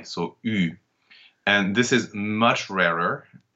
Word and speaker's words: eu: So if you see EU on its own, this is the past participle of the verb to have, and it will be pronounced U, as in j'ai eu, I've had.